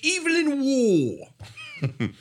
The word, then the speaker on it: Evelyn Waugh: Whereas Joseph says it, well, you know, Michael, what to pronounce it correctly is Evelyn Waugh.